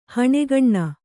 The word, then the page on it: ಹಣ್ಗೊನೆ: haṇgone